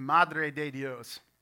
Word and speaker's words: Madre de Dios: That is the state of Madre de Dios, which uh, Pastor Scott perfectly pronounced.